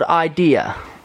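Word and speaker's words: Eyedea: Have you heard of a guy called Idea? But it's spelled like I, like E-Y-E, and then Dia.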